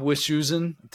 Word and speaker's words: Wischusen: Bob Wischusen, did I pronounce Bob's last name right? – Good job. Yeah.